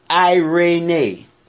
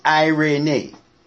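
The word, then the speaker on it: irene (εἰρήνη): The Greek word is irene. It's pronounced irene. Not the way I pronounce it, it's spelled. That's the way you pronounce it. Irene.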